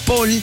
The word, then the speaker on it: Paul: Recuerda que mi nombre se pronuncia Paul, pero se escribe Paul, P-A-U-L.